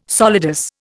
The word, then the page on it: solidus: Indian